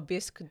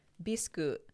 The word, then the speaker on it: Bisque: So the Japanese pronunciation would be Bisque, Bisku, but here in the U.S., you would say bisque. – Or if you're French, you would also say bisque.